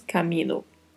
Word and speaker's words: camino: A única particularidade de pronúncia aqui é a letra A, que é mais aberta. Pronunciamos camino. Por, que é uma preposição, atenção ao vibrato, por.